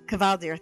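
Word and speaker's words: Kvaldir: In this particular deck, you're going to have two injured Kalvir. How do you pronounce it? – Kavaldir. – Kavaldir. Thank you.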